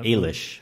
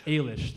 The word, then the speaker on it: Eilis: Eilis. – Eilis, thank you.